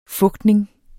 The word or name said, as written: fugtning